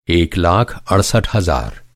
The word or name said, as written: अड़सठ